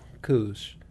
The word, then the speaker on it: Kölsch: You gotta learn how to pronounce that umlaut. Yeah. Kölsch. I'm not saying it right.